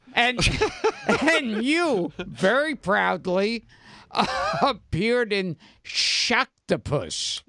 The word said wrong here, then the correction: Sharktopus: No, you must say it correctly. – Oh, sorry. – Sharktopus.